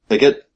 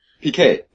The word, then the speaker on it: Piquet: Is that how you pronounce that? PK? – PK? – Yeah. – Yeah. – That's another silent T. – Is that correct? PK? – Picket, you mean? – P-I-Q-U-E-T? – PK, yeah.